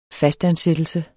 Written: fastansættelse

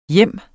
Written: hjem

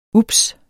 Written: ups

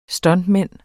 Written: stuntman

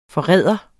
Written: forræder

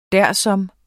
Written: dersom

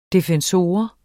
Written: defensor

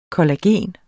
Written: kollagen

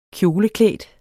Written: kjoleklædt

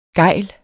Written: gejle

